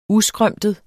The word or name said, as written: uskrømtet